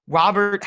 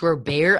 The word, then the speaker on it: Robert: Pronounced Luis Robert. I think he's fine with Robert. No, he's fine with Robert, and that's like how Americans pronounce it. But like the correct pronunciation in Cuba is Luis Robert, and I know we're a word pod, so I just have to bring that to our attention.